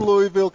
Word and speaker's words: Louisville: That's how you can tell if somebody's really from Louisville, Kentucky. Yeah. They say Louisville. – Yeah, and it's not a three-syllable word.